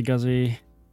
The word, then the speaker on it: Guzzi: Moto Guzzi, yeah, Guzzi.